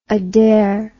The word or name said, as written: Adair